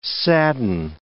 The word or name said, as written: sadden